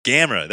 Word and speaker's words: Gamera: Gamera, there you go. That's how you say it. – Yeah, it's Gamera. – It's like game with an R and A.